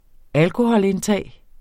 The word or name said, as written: alkoholindtag